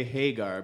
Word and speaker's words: Hagar: I would say Hagar because it's, yeah, said, it's spelled the same as Sammy Hagar.